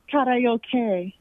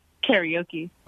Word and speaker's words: karaoke: That's how my grandmother would pronounce it. She was Japanese, and she used to get mad when people would say karaoke. – Oh, so it's not, how do you say? – She said this. – So how do you say it? – She said it's karaoke.